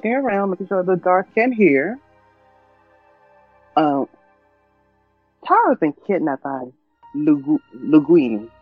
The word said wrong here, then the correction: Ludini: Ludini. Think of Houdini. – Okay. Taro's been kidnapped by Ludini.